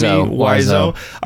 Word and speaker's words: Wiseau: I feel like at the end of the day, I kind of feel sorry for, how do you pronounce his name? – Wiseau. – Tommy Wiseau.